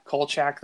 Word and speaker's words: Kolchak: Yeah, Kolchak the Night Stalker.